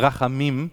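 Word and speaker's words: Rachamim (רחמים): Das hebräische Wort Barmherzigkeit bedeutet Rachamim, oder es heißt, wird ausgesprochen Rachamim.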